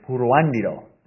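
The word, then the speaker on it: Puruándiro: Yeah, Jim got the first syllable right. It's Puruandiro. But it's a hard one to pronounce.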